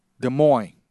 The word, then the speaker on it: Des Moines: Des Moines. Is that how that's pronounced? Is it with the S without the pronunciation of the S? – You got it right.